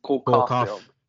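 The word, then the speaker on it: Cole Caufield: It's because they gave Shea Weber Cole Caulfield, which is a very easy name to say. – Oh, he said it wrong anyways. – Did he? Oh, yeah, he did. – Cole Caulfield. Jeez.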